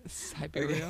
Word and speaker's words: Siberia: Siberia.